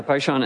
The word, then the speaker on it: Pishon: But after that, it gets a little bit more difficult, because the other two rivers, the Pishon and the second one, the Gihon, I think I said it wrong. Hang on. Yeah, the Paishan and the Gihon.